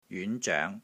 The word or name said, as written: JYUN5 ZOENG2